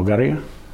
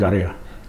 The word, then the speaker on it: Garia: Well, Garia, or Garia, if we use no our way of speaking, Garia, is the name for a crocodile.